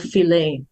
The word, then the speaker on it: Philae: This is the island of Philae. And the island of Philae. It was an island. – Philae. Sometimes we say Phila in Arabic and Philae in English.